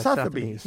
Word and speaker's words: Sotheby's: Sotheby's. Yeah, Sotheby's. – Sotheby's. It's kind of a funny... It's so the buys.